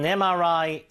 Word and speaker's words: MRI: He got an MRI scan of his brain. Okay? 자, 여기도 왜 on이 들어가는지 한번 잘 보세요. 철자가 M이지만 읽을 때 어떻게 읽어요? 그쵸? A로 읽죠?